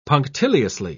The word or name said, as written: punctiliously